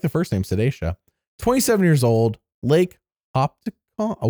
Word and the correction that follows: Hopatcong: Ooh, I'm not saying that right. Lake Hopatcong.